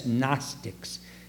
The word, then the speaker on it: Gnostics: The Gnostics, G-N-O-S-T-I-C-S, Gnostics. Or Gnostics, if you don't know how to pronounce it.